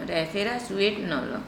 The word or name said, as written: rEpHel suit nOl